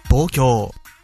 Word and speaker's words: Tokio: Lo he buscado y según Wikipedia, Tokio se dice así en japonés. Tokio.